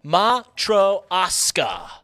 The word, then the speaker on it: Matryoshka: It is pronounced Matryoshka doll.